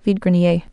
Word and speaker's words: Vide Grenier: I don't know the phonetic alphabet, but here's my best explanation of the pronunciation. Vide Grenier.